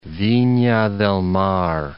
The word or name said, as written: Vina del Mar